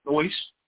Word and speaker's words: nous: When you look in the Strong's Concordance and you look in the Greek version, the Greek word for capacity is gnosi. I think I'm saying that right. It's nous, N-O-U-S.